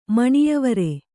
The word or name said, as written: ಮಣಿಯವರೆ